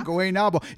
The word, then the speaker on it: Guaynabo: Guaynabo.